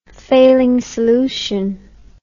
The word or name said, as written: Fehling solution